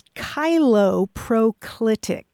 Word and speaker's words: cheiloproclitic: The word is chiloproclitic, and I will spell it.